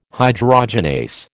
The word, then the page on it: hydrogenase: American English